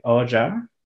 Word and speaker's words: Ojha: It was directed by Rajshree Oja. I think it's how you pronounce the last name, O-J-H-A.